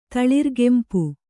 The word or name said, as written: ತಳಿರುಗೆಂಪು